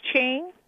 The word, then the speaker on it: Ching: No, it's Ching. – It's Ching. – It's Ching. Ching. Like cha-ching? – Uh-huh.